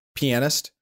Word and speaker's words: pianist: Is it pronounced pianist? I thought it was pronounced penis. – No, I don't think so. – The A is in there. You don't have to emphasize it as much as I did. It's like pianist, but that A still needs to make it in.